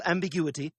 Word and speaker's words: ambiguity: The word has five syllables, and if you want to learn something about syllable stress, when the word ends in I-T-Y, the stress is going to be on the third to last syllable, that U, ambiguity, ambiguity. The next noun is this one right here, diarrhea.